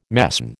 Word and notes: mesne: American English

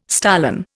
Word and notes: stolon: Indian

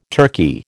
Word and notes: turkey: American English

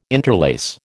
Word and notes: interlace: American English

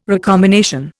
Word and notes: recombination: Indian

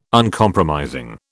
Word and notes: Uncompromising: British English

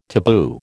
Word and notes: taboo: American English

